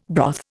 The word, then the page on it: broth: Indian